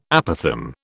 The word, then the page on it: apothegm: British English